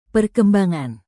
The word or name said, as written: perkembangan